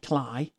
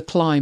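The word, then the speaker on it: Clié: Oh, I had a Sony Cly. I think it's pronounced Cly.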